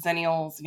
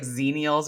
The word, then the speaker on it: zennials: I used to pronounce that as like zenials or something. And then someone on TikTok very not so kindly told me I was saying it wrong.